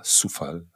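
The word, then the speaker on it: Coufal: Und das gleiche auch bei Kufal, der ja Sufal heißt, und Mittelstädt.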